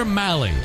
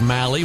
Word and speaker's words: Mahle: Tyler Malley, last name spelled M-A-H-L-E, and pronounced Malley, rhymes with rally. Heard a few different pronunciations, and Malley is the one that he goes with.